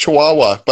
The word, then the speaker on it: Chihuahua: Chihu. Oh no, it's just Chihuahua, but I think it's spelled wrong. oh no. – C H I H U A H U A. – Is yeah, that Chihuahua? – Right.